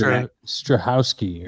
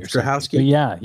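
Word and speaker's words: Strahovski: Yvonne Stravosky, who is Strahowski. – Strahowski.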